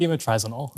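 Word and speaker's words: bemotrizinol: You have to pronounce it for me. Is it Bimotrizole? – Bimotrizinol. – Yes, exactly.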